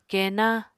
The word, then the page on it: kenna: Apatani